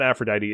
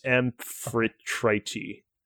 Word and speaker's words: Amphitrite: no, it's not Aphrodite. It's Amphitrite.